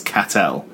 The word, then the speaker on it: Castel: This is really, really boring for everyone, but we have a parish in Guernsey spelt Castel, which we pronounce Catel.